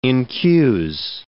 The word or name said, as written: incuse